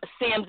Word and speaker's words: Sims: T-E-R-R-I-E, Terrie, and then Sims is spelled with 1 M, S-I-M-S.